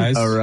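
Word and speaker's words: Arráez: Arias. – Luis Arias. Whatever it is. Yeah. I mean, Jesus Christ. But Luis, how do you say it again? – Arise.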